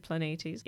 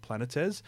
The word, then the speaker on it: Planetes: I'm glad you can actually pronounce that properly, because for years I've been calling it Planetes. – Ah. – But it's actually written with Greek letters and I couldn't do it right.